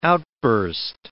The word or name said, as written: outburst